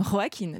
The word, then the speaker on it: Joaquin: Et donc vous dites Joaquin Phoenix. Et je dis Joaquin Phoenix. c'est une puriste. Hein. Lorsque je l'ai croisé, puriste de la prononciation. Hello, I'm Joaquin.